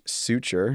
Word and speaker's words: Sutchar: And we have Josh Sutchar.